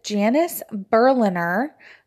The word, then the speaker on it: Janice Berliner: I am here with Janice Berliner.